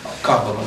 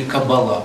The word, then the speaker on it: Каббала: Каббала, вот еще вспомнили. Или Каббала по-другому, да, Каббала хорошо звучит. Вот есть Каббала, да, есть Каббала. Только небольшое ударение, и все как-то меняется.